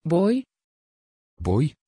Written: boy

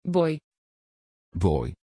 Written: bowy

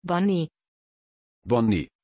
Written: bonnie

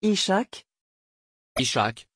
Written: ishaq

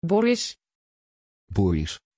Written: borris

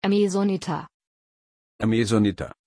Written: amazonita

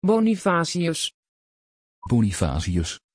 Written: bonifazius

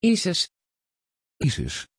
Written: isis

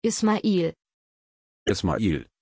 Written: ismail

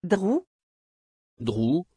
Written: Drew

